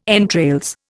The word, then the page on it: entrails: Indian